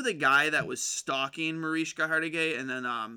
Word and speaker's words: Mariska: I'm pronouncing her name right, Mariska? – Mariska Hargitay.